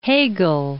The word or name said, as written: Hegel